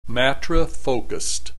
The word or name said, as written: matrifocused